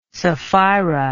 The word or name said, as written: sapphira